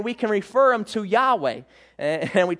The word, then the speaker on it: Yahweh: Most people pronounce it as Yahweh.